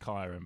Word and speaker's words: Kyran: It's pronounced Kyron, but he was that was the closest I had.